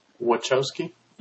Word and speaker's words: Wachowski: Wachowski.